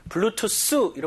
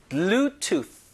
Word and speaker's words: Bluetooth: 자, 이 발음이 TH 때문에 블루투스 이렇게 하는 건 조금 영어에서 많이 벗어나고요. Bluetooth 하면서 혀끝을 윗니, 아랫니 사이에 넣으면서 공기를 막아야 돼요. 자, Bluetooth.